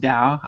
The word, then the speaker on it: Tao: It includes an expression from the Tao, which is spelled T-A-O, but I believe it's pronounced Tao. I always pronounce it Tao.